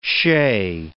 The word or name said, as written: shay